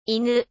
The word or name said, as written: inu